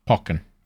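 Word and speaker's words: Pokkén: Pokken.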